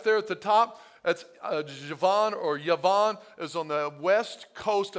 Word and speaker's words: Javan: It's spelled J-A-V-A-N, but it's pronounced Yavan.